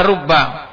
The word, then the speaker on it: rubba: Bidhammir ra wa fathil ba al-musyaddadah, dengan dibacanya dengan ra-nya itu di dhammah. Karena apa? Karena kalau kalimat ra sama ba dalam bahasa Arab bisa dibaca rabbi, bisa dibaca robba, bisa dibaca rubba. Gitu.